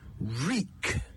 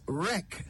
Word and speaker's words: wreak: The word is reek. W-R-E-A-K. Reek. Many people pronounce it as wreck, but that is an entirely different word with an entirely different meaning.